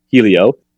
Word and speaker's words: Hylio: It's spelled H-Y-L-I-O, which I know looks like Hylio, but it's pronounced Helio.